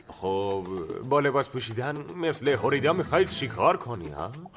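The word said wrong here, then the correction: Frida: هوریدا نیستش، فریدا تلفظش، مثل فری فری انگلیسی. فریدا فریدا کارلو گرفتی؟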